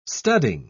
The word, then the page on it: studding: British English